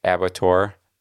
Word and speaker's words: abattoir: By the way, I looked it up because I wanted to know how to say it, if it was Abator or abattoir, or what, because it looked French-ish, right? With the O-I-R kind of thing. But no, it's abattoir.